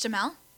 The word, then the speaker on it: Duhamel: Oh, Josh Duhamel? – Yeah, there you go.